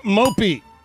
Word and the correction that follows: Mbappe: Please pronounce that correctly. – I did. – What did you say? – Killian. – Uh-huh. – Mopey. Moppy. Moppy. – Mbappe. – Mbappe. Mbappe. That's an M, though.